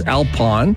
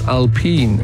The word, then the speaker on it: Alpine: Jared says in Formula One racing they have a team spelt as Alpine, but they pronounce it as Alpine. And yeah, that's because it is a French subsidiary. It's a car that's, I think, a part of Renault or Citroën or something. And it's French, and the French say Alpine, not Alpine.